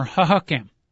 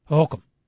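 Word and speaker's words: Hohokam: Is it Ho-Ho-Cam or Ha-Ha-Cam? – Ho-Ho-Cam. Okay, yeah, exactly, Coach. You got it.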